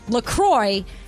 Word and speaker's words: La Croix: And they even say on their website, it's definitely pronounced La Croix. And if you ever get confused about it, just remember it rhymes with enjoy.